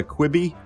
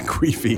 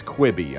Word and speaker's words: Quibi: Are you familiar with this Quibi? Quibi? I don't no. – really know how it's Quibi. Pronounced. Quibi? – Queefy? – Not Queefy. – No. – Q-U-I-B-I.